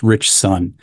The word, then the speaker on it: Ritchson: Uh, but mostly, I like seeing Alan Ritchson. Rich, what's his name? Ritchson. It's a hard name to yeah. pronounce. Rich Son.